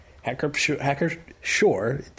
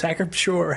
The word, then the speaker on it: Pschorr: This is brewed from Hacker Shore. It's Hacker Shore or Hacker Shore. Our German friend says you can pronounce the P or not pronounce the P.